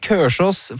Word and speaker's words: Kolsås: Men stedsnavnet Kørsås, eller bru å si Kørsås for Kolsås, ifølge Wikipedia, så det den tradisjonelle uttalen, å si Kørsås på tradisjonell, gammel, lokal bærumsdialekt.